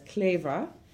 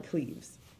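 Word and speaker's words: Kleve: They pronounce it as Cleva, but we call it Cleves.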